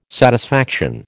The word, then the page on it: satisfaction: American English